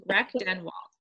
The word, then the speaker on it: Rechtenwald: So that there you go. Exactly how it's spelled. Rechtenwald.